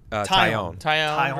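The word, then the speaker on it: Taillon: So it's Talion versus Caprillion. Did I say that right? Talion. – Tyone versus Caprillion, Cole versus Manaea, Cortez versus Montas, and Montgomery versus Blackburn.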